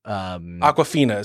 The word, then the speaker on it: Awkwafina: Awkwafina is how you pronounce it.